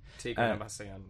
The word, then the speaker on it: Tigran Hamasyan: You've chosen, now hopefully I'll pronounce this right, Tigran Hamasyan? – Tigran Hamasyan, yeah.